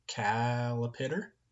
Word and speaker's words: Calipitter: My favorite song is Calipitter, which is like a little kid would pronounce caterpillar, so it's Calipitter.